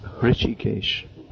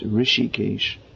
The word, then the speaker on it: Rishikesh: Now, often devotees, they mispronounce this as Rishikesh. And some people say that the name has derived from the name of the Supreme Lord, Hrishikesh, beginning with an H. Actually, the name is Rishikesh.